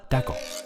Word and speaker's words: taco: 在墨西哥，准确的发音应该是 taco。 taco 主要由三部分组成，温热软韧的玉米饼，焦香四溢的烤肉，还有各种各样的秘制酱料。 taco 的种类也多种多样，鸡肉、牛肉、猪肉任选，口感丰富。